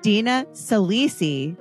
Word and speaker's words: Dina Saalisi: So Dina Saalisi, who, by the way, everyone, I pronounced her name right on the first try, right, Dina? – Yes, you did.